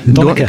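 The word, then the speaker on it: Donnacha: Dus Donache... Donneke. – Donneke, Donneke oh, sorry. Zo moet uitspreken.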